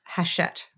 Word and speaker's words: Hachette: Now, Forever is part of Hachette, right? – It is part of Hachette.